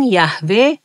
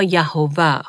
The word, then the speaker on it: یهوه: امروزه به اون حروف صدادار اضافه شده و بنابراین یهوه یا یهوه خونده میشه